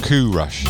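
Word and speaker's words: Qua Rush: It's spelled Q-U-A Rush. And when I asked him, he said Kua Rush.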